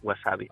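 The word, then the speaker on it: wasabi (山葵): O sigui, llegeixen Yama Aoi, però diuen wasabi.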